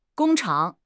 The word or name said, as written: gong1 chang3